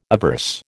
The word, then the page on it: averse: American English